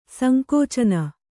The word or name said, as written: ಸಂಕುಚನ